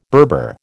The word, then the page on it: Berber: American English